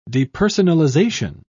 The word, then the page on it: depersonalization: British English